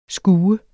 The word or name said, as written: skue